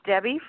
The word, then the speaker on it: Debbie: My name's Debbie from Louisiana. – Hey, Debbie. – Hi. – D-E-B-B-I-E or D-E-B-I?